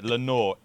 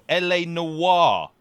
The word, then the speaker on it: L.A. Noire: How'd you pronounce it? Lenore. L.A. Noire.